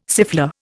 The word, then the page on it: siffleur: Indian